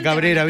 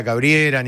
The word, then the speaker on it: cabrera: Yo digo la cabrera bien, te lo digo bien, la cabrera. – Sí, claro. – No te digo la cabriera ni nada raro.